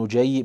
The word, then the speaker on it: نجي: طيب، ومنها عبد الله ابن يحيى وهم جماعة، عبد الله بن نجي بضم النون وفتح الجيم وتشديد الياء، تابعون معروف، يروي عن علي رضي الله عنه.